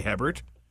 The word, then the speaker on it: Hebert: But he wasn't French either, and he actually grew up with it being pronounced Guy Hebert.